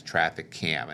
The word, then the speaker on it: TraffickCam: So the Traffic Cam app is spelled TRAFFICKCAM, and that's Traffic Cam, and that'll help you look up that app when you go to try to find it on your app store.